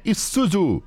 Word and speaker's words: Isuzu: É, Diego, você escreve Isuzu, mas a pronúncia é Isuzu.